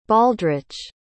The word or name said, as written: baldrich